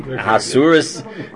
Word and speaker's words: Ahasuerus: Ahasuerus, king, right, king over 127 countries, right, etc.